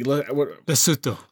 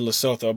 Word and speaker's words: Lesotho: Like Lesotho. – Lesotho? Is that how you say it? Okay. I always said Lesotho, but we all know I'm terrible with pronunciation.